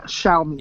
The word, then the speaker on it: Xiaomi: Xiaomi.